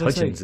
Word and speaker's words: Hudgens: What did Hudgens. – I say? – Vanessa Hudgens. – Vanessa. Anyway, Vanessa Hudgens